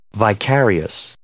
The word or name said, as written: vicarious